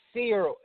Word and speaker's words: Searle: This is Sills? – It's Byron Searle, S-E-A-R-L-E.